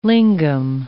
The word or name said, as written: lingam